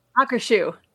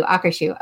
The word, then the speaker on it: Akershus: Akershus. – Thank you. Akershus.